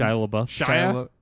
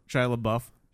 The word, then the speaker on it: Shia LaBeouf: Shia LaBeouf. – Shia LaBeouf.